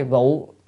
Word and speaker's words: vụ: Cái chữ này là vũ hay vụ. Vũ là mưa, là cơn mưa. Mà danh từ phải đọc là vụ, hoặc đọc dấu sắc của vũ á, thì mới là động từ.